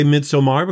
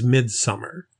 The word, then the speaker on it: Midsommar: We always say Midsommar because that is the British or European pronunciation. The American accepted pronunciation for this is Midsummer. So you'll see some reviewers and people out there who call it Midsummer, even though it has two M's and an A-R, not an E-R in it.